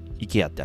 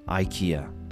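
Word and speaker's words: IKEA: はい、まずね皆さん、必ず行ったことあると思うんですけど、あのウクライナみたいなね色の黄色と青のイケアってありますね。でこのイケアは、日本語ではねイケアって言いますけども、アメリカでは、全然違う発音になります。それは何かというとメモ用意してくださいね。イケアではなくてアイケア全然違いますよね。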